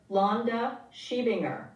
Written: Londa Schiebinger

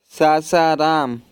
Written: Sasaram

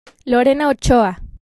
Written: Lorena Ochoa

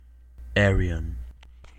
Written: Ayreon

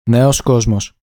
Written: Neos Kosmos

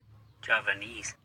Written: Javanese

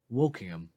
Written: Wokingham

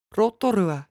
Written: Rotorua

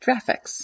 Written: Jraphics

